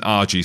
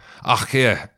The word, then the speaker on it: RG: And it isn't RG Snyman. It's Arkir Snyman.